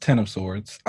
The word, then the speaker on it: X of Swords: it's it's pronounced Ten of Swords. Um, okay. It is because Tini said that you know when they were doing Hox Pox that it was House of X and powers of 10.